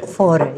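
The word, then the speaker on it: Fårö: Forer? Okay. – Forer, yes.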